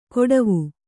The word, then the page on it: ಕೊಡವು: koḍavu